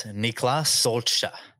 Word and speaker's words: Niklas Stolze: But the way that you say his name is Niklas Stolze.